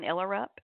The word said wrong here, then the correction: Ullerup: The gentleman in the commercial said Uluroop. And her last name is spelled Ullerup.